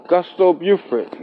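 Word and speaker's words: Justo Beauford: So my cash app is Gusto Beauford.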